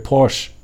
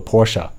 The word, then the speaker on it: Porsche: 很多人说它是 Porsche，但是它正确的读法，我认为，至少我认为是应该读作 Porsche 啊。